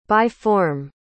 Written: by-form

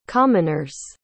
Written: commoners

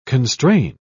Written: constrain

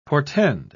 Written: portend

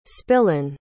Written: spillane

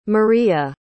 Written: Mariya

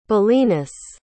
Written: bellinus

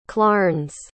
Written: clarnce

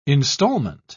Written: installment

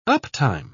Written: uptime

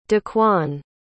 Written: daquan

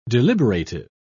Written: deliberative

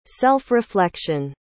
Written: self-reflection

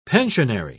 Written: pensionary

